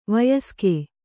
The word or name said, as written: ವಯಸ್ಕ